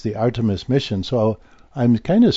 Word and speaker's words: Artemis: And so this is this armistice, the, not armistice, excuse me, I'm going to pronounce it correctly. Artemis.